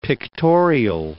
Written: pictorial

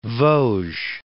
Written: Vosges

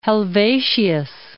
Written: Helvetius

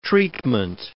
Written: treatment

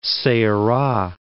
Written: Ceara

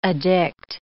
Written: addict

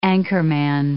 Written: anchorman

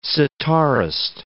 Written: sitarist

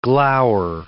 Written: glower

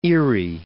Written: Fort Erie

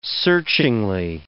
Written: searchingly

